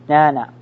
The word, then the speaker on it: Jnana: It's spelled J-N-A. N-A, J-N-A-N-A, Jnana. Or you call it Jnana. It's J-N-A-N-A, Jnana, Yoga. The word, the Sanskrit word Jnana means knowledge. This yoga, yes. – J-N-A, another N-A.